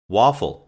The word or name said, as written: waffle